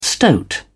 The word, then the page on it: stoat: British English